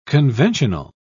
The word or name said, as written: conventional